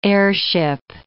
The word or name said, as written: heirship